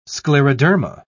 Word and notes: scleroderma: British English

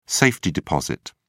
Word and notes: safety-deposit: British English

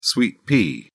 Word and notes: sweet pea: British English

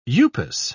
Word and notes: upas: British English

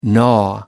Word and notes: gnaw: American English

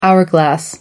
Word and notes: hourglass: American English

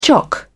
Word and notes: chock: British English

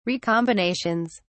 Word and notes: recombinations: British English

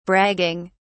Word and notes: bragging: British English

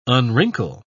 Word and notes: unwrinkle: British English

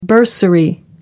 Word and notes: bursary: American English

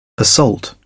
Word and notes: assault: British English

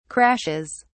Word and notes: crashes: British English

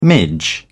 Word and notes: midge: British English

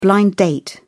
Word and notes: blind date: British English

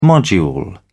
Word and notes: module: British English

